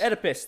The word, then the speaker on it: Oedipus: Oedipus, there it is.